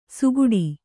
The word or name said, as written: ಸುಗುಡಿ